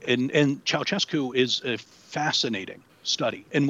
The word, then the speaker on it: Ceaușescu: And how do you pronounce his last name? Kosescu? – Ceaușescu. – Ceaușescu.